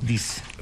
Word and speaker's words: disc: Hoy, un día como vi, Sony lanzaba su primer lector de Compact Discs. – ¿De qué? – Del Compact Disc. – Disc. – Discs. En Paraguay. – No, espera. Dix no es. D-I-S-C. Claro, disc.